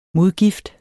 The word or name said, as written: modgift